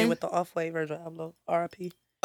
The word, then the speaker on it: Abloh: Virgil Abloh.